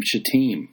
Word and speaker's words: Shittim: Early in the morning, Joshua and all the Israelites set out from Shittim. Okay, and by the way, it's a long I.